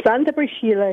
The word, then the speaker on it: Santa Priscilla: Is that how you pronounce it in Italian? Santa Priscilla? – Santa Priscilla it is, yes.